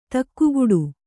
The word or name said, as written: ದಿಗ್ಗೇಡಿ